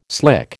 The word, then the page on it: slick: American English